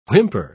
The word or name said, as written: whimper